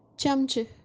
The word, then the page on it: chamchi: kashmiri